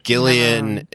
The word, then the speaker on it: Gillian: And Gillian, it's a soft G with the one from Community. Gillian Jacobs.